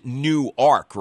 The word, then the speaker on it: Newark: Actually, I'm supposed to pronounce it Newark, right? I've been told that by a couple of listeners down there.